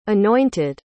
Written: anointed

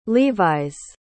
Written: levi's

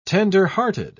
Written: tenderhearted

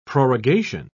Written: prorogation

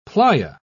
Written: playa

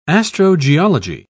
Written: astrogeology

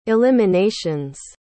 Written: eliminations

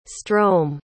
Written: strome

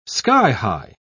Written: sky-high

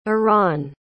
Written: eran

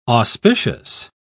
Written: auspicious

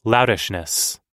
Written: loutishness

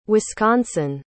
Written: wisconsin

